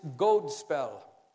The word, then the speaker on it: godspell: Actually, it comes from Old English, and the word is godspell. Long O, gold spell, which literally means a good story.